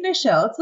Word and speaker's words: Nichelle: No, it's Nichelle. It's just like Michelle, only with an N.